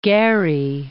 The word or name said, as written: Gary